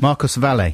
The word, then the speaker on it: Marcos Valle: Marcos Valle.